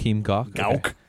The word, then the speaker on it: Gauck: So the federal president, hopefully I say this right, Joachim Gauck. – Gauck.